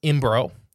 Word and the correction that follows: Imbrogno: So, I'm saying his name wrong. Imbrogno? It's I-M-B-R-O-G-N-O. – Imbrogno? I don't know. – Imbrogno. I'll go with Imbrogno and Hynek.